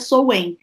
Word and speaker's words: Samhain: é engraçado porque escreve Samhain, né? S-A-M-H-A-I-N, mas se pronuncia Soen.